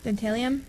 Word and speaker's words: Dentalium: Dentalium? – Dentalium.